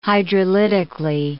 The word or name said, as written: hydrolytically